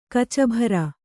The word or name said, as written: ಕೂಚುಭೇರಿ